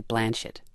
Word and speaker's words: Blanchett: It's actually Blanchett.